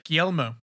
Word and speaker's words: Guillermo: Guillermo.